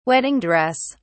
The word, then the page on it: wedding dress: British English